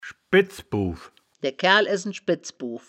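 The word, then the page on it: schbedz-bow: Wittlich dialect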